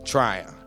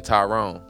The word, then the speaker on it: Tryon: How's it pronounced? Tryon? – Tryon. – Tryon, North Carolina. At first, I was saying Tyrone. You know, I was switching some of the letters around.